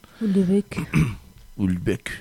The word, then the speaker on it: Houellebecq: Yo creo que lo digo mal, pero a mí me suena bien. – Houellebecq.